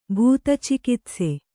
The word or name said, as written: ಭೂತಚಿಕಿತ್ಸೆ